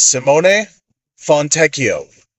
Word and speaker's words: Fontecchio: So this is how you say his name. You'll hear people say all kinds of ridiculous things. Okay, his name is Simone Fontecchio.